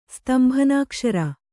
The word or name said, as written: ಸ್ತಂಭನಾಕ್ಷರ